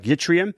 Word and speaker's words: yttrium: The nanoparticles in this study were made from rare earth ions like yttrium. That's how you pronounce it, right, Steve? Y-T-T-R-I-U-M.